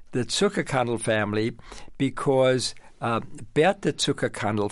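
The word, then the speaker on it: Zuckerkandl: One of the things you write about is the Zucker – we talked about the salons, the Zucker Candle Salon. – I love that. – Did I pronounce that correctly? – You did it very well.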